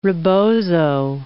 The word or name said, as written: rebozo